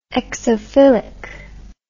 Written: exophilic

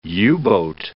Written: U-boat

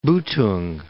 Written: Boetoeng

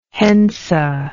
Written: henze

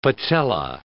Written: patella

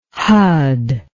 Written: hud

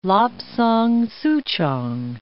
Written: Lapsang souchong

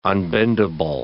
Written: unbendable

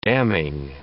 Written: damning

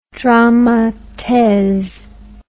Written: drame a these

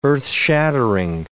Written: earth-shattering

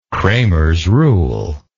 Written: cramers rule

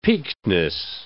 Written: peakedness

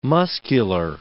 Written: muscular

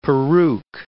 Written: peruke

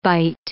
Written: bite

